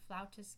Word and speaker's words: flautist: I believe it is flutist because we play the flute, especially in America. And maybe many European countries, it's pronounced flout, and that's why where the word flautist kind of came from. So, you know, it's both are fine. Both are accurate.